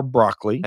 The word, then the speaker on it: Broccoli: So Albert R. Broccoli, and yes, that is how he pronounced his last name, who was nicknamed Cubby, by the way, was born on April 5th, 1909 in New York City.